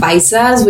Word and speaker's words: paisa: Speak P-A-I-S-A. – Paisas. – Paisa. – Paisa. Mm-hmm. Oh, the last S is silent. – Paisa, and then if it's plural, paisas with an S.